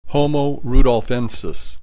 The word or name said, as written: Homo rudolfensis